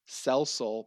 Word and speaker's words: Cell Soul: And then the global community, which is now the name of the UN, starts a new program called Cellular Solar, aka Cell Soul, pronounced like you're going to sell your soul.